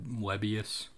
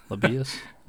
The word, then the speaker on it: Lebbeus: How do you say his name? Web Webius. Lebbeus. Lebbeus Overton, number one player in the 2023 class, visited during the orange and white game.